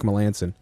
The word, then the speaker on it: Melanson: His name is fucking always irritated me, because the way it's written, it should be pronounced Melancon. – That's what I thought. – But no, this motherfucker is like, it's Melanson.